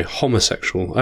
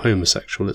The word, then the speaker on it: homosexual: Homosexual. I actually only just found this out recently. It's not homosexual, it's homosexual. – Is it? Okay. – Yeah, because it's the Latin.